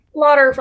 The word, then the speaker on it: Lauter: Lauter rhymes with water.